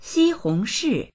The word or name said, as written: xi1hong2shi4